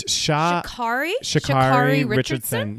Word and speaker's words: Sha'Carri Richardson: It's Shakari Richardson.